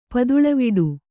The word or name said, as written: ಪದುಳವಿಡು